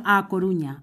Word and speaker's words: A Coruña: Siguiente parada, La Coruña. Bueno, a Coruña. – A, Coruña. Eh, lo he dicho mal, sí, señor.